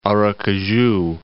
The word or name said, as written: Aracaju